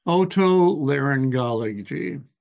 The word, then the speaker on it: otolaryngology: How do you pronounce that? – Otolaryngology.